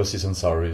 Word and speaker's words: Aziz Ansari: Ich glaube, Aziz Ansari ist okay.